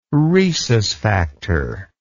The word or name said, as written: rhesus factor